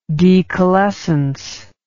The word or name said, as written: decalescent